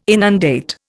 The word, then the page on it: inundate: Indian